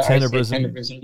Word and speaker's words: Tenebrism: But she's the daughter of Justify, out of Immortal Verse, which makes her a half to Tenebrisium, or Tenebrism, whichever the pronunciation is. – Yeah, Tenebrism. Yeah.